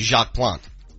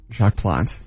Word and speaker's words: Jacques Plante: I'm not a hockey purist, but I think the proper pronunciation is Jacques Plante. – Jacques Plante, pardon me.